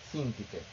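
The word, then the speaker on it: Síntique: Sintiki, porque la verdad lleva un acento en la primera I, por eso debe ser Sintiki.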